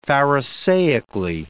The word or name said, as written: pharisaically